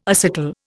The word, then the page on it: acetal: Indian